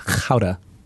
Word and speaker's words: Gouda: But the farmers who like made the cheese were like, oh, it's actually pronounced Gouda. I think it was Gouda. No, Gouda.